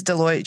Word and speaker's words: Deloitte: If you look at the statistic from Delwois, they mention... – It's Deloitte. – Is it Deloitte? Oh, I've been mispronouncing it for years.